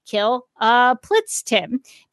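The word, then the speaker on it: Kltpzyxm: Wonder Woman uses Bizarro's duplicator ray on Mxy and creates a Bizarro Mxy, whose name is actually pronounced Kill Plitztim, because as a Bizarro, his name would be backwards.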